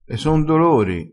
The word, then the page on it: e son dolori: dialettale